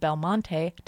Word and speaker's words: Belmonte: Ben is a tenor soloist who's in town to play the leading role of Belmonte.